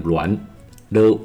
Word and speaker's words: 栾: 台湾栾树这个字念栾，l u an 栾。